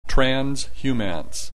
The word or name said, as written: transhumance